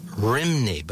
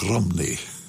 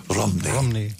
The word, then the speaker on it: Rhymney: We knew it for years, Pete, or we called it the Bells of Rimney, but that's not accurate, is it? – That's how it's spelt. R-H-Y-M-N-E-Y. But there they pronounce it more like Rumney. Rumney.